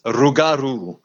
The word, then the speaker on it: Rougarou: They pronounce it Rougarou, and it's just like the most amazing pronunciation. I can roll my R's because I'm from South Africa, so it's kind of a kind of guttural Afrikaans, but I love them.